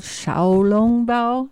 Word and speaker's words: Xiaolongbao: And, you know, there's a lot of different ways to make dumplings, but there's one called Xiaolongbao, and that's actually how you say it in Chinese.